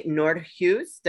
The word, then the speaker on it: Nordhues: Please welcome Amy Nordhuis. Did I say that properly? – You did.